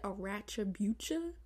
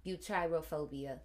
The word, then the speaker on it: arachibutyrophobia: You said arachibucha? – Butyrophobia.